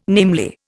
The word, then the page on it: namely: Indian